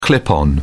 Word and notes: clip on: British English

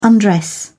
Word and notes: undress: British English

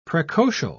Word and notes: precocial: British English

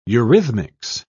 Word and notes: eurhythmics: British English

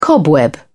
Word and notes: cobweb: British English

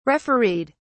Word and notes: refereed: British English

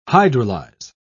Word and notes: hydrolyze: British English

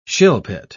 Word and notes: shilpit: British English

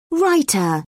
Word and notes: raita: British English

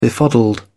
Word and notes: befuddled: British English

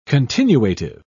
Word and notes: continuative: British English

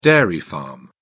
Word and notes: dairy farm: British English

